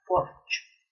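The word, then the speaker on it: porxo: en torno a un espacio principal llamado porcho. se pronuncia así.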